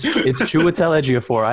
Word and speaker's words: Chiwetel Ejiofor: And the four of them all together go to Chiwetel Ejiofor for a mission. – Good for you, dude. – I brought the receipt. – Relish trying to pronounce his name. – It's Chiwetel Ejiofor.